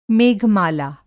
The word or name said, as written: मेघ-माला